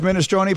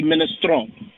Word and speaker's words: minestrone: Any Italian word that has a vowel on the end, that vowel gets used. So if the E is actually part of the word minestrone, then that E gets used.